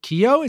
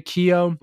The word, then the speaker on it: Keoghan: Starring Dev Patel, Alicia Vikander, Joel Edgerton, Sean Harris, Ralph Ineson, Barry Keoghan. Is it Keoghan? – Keough? – Is that how you say it? – Yeah, Barry Keough. – Like Riley Keough. It's the same thing.